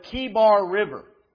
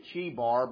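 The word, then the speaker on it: Chebar: on the bank of the Kibar River. I think I'm pronouncing that right. Some people probably read it Chibar, but in the Hebrew, I think it's more of a hard sound.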